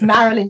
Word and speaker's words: Marilyn: It's Marilyn Devenish. It's MARILYN, and Devenish is DEV for Victor, ONISH.